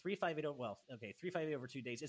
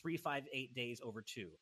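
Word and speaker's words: 358/2 Days: According to Nomura, 358 over 2 days. It's pronounced 358 days over two.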